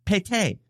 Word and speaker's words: pété: which is pronounced pété.